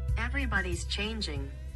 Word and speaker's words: Everybody's Changing: ¿Cómo se pronuncia esto? A ver. – Everybody's changing. – Ah, está bien, lo dije bien. Everybody changing. ¿Es así? – Everybody's changing. – Changing.